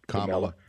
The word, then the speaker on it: Kamala: One, Kamala, I think it's pronounced Kamala. – Kamala. Kamala, okay.